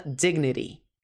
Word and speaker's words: dignity: Sentence 11, frase 11, uma frase dramática, dignity, pronúncia americana, dignity, esse T no final soa como ri, então, my dignity isn't, o que é isn't?